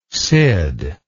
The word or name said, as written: sid